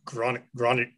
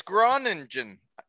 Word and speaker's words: Groningen: To the Gids Groningen of the Netherlands? – I believe it's Groningen. Now I can't say it's Groningen. – Groningen.